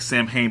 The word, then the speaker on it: Samhain: Spelled Sam like Sam Hain, but it's pronounced Samhain.